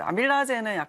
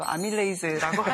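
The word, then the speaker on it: amylase: 이제 아밀라제는 약간 좀 구식 발음이고 아밀레이즈라고 하는데.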